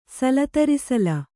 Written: ಸಲತರಿಸಲ

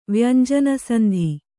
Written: ವ್ಯಂಜನಸಂಧಿ

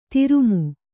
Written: ಧರುಮ

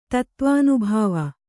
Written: ತತ್ತ್ವಾನುಭಾವ

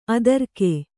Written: ಅದುರುಗ

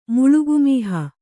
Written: ಮುಳುಗುಮೀಹ

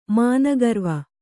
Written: ಮಾನಗರ್ವ

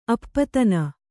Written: ಅಪದಾನ

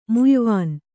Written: ಮುಯ್ವೊನೆ

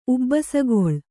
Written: ಉಬ್ಬಸಗಳಿ

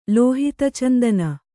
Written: ಲೋಹಿತಚಂದನ

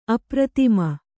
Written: ಅಪ್ರತಿಮ

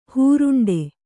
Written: ಹೇರಂಡ

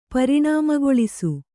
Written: ಪರಿಣಾಮಗೊಳಿಸು

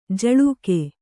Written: ಝುಳುಕು

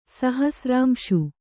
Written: ಸಹಸ್ರಾಂಶು

